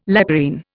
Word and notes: leporine: Indian

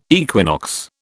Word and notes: equinox: British English

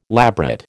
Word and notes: labret: American English

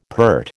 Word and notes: peart: American English

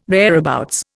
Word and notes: thereabouts: Indian

